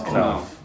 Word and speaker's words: Knauf: Oh, it is Knauf. Knauf.